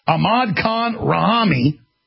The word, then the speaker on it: Ahmad Khan Rahami: We have Ahmed Khan Rahim. Did I pronounce that right? Rahami. Ahmad Khan Rahami.